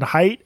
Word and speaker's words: Haidt: Who would win in a fist fight between Jonathan Haidt and Jordan Peterson? – Oh my God. Well, first of all, I'm glad you pronounced Jonathan Haidt's name correctly. – Everyone gets that wrong.